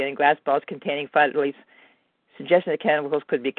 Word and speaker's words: phthalates: It's got chemicals called phthalates.